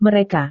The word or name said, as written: mereka